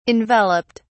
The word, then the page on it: enveloped: British English